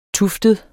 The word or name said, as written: tuftet